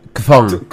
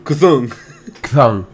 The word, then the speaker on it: Chthon: que es no sé cómo pronunciarlo, es C-H-T-H-O-N. – Ah, Kthong. – Kthong, ajá.